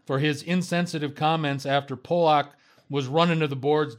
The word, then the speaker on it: Polak: In fact, Dallas Stars defenseman Roman, am I saying this correctly, guys? Polak? Is that what it is? – Yeah. – Roman Polak,